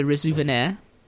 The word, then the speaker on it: Resouvenir: he got married in 1812 at the age of 16 to a slave on the neighbouring plantation of Le Resouvenir. And that's how I'm going to pronounce it. It's R E S O U V E N I R.